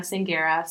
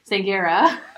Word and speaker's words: Sanghera: So you can find me personally on Instagram, my name, Christina Sanguera. So Christina, just C-H-R-I-S-T-I-N-A, Sanguera. Let me spell that one. Is S-A-N-G-H-E-R-A.